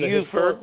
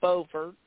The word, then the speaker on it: Beaufort: And then Beaufort, not Beaufort. – Nope. – Oh, North Carolina. – Yeah. – It is different in North Carolina.